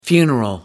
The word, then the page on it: funeral: American English